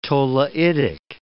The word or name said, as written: tholeiitic